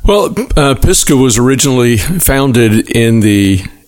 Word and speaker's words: Pisgah: Pisgah? – Mm-hmm.